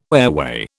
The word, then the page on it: airway: British English